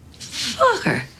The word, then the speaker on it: Focker: How do you pronounce it? – Oh, just like it's spelled. F-O-C-K-E-R. – Focke. – Focke.